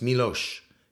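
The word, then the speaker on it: Miloš: And it's not Milos. It's Milos Karadoglic.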